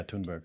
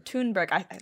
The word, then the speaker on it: Thunberg: Yeah, Thunberg. – Thunberg. – That's how I've heard it pronounced.